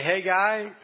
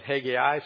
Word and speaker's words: Haggai: As we finish the book of Ezra, you will remember, I trust, that Ezra talks about two prophets, the prophet Haggai, or Haggai, some pronounce it, and the prophet Zechariah.